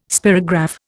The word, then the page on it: spirograph: Indian